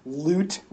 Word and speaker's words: Lute: And then the lowest is Lute Boone. His name is spelled L-U-T-E. – Ah, like the instrument.